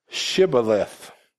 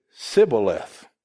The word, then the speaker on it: Shibboleth: It was a time when they were against the Ephraimites, and it says in Judges 12:6, then said they unto him, say now Shibboleth. And he said, Sibboleth, for he could not frame to pronounce it right. Then they took him and slew him at the passage of Jordan, and there fell at that time of the Ephraimites 42,000. He could not pronounce the H because of where he was from.